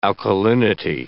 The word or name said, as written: alkalinity